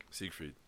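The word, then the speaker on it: Seigfried: Is it like Siegfried? – That's how you say it.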